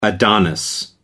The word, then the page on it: adonis: American English